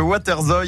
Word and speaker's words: waterzooi: Le waterzooi est un plat mijoté à base de poulet ou de poisson.